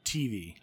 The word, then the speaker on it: TV: TV, though, is the pronunciation should be on the T. TV.